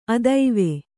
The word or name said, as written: ಅತ್ವ